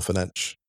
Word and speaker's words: Fernandes: Cavani got a lovely header at the far post, beautifully constructed goal, a great swirling strike by Bruno Fernandes, or Fenech, correct pronunciation.